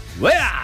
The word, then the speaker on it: Weah: Weah. – Weah.